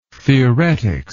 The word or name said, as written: theoretics